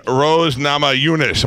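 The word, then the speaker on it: Rose Namajunas: This is Tisha Torres and Rose Nama Yunus.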